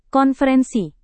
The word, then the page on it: konferensi: Indonesian